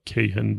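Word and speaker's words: Kahane: آدم کاهان یا آدم کیهن به تلفظ خودشون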